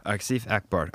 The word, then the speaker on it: Asif Akbar: Asif Akbar.